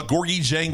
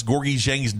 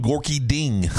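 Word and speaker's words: Gorgui Dieng: Okay, he was at Louisville games, and he didn't know how to pronounce Gorgie Jang's name. He said he called him Gorky Ding.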